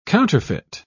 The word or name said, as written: counterfeit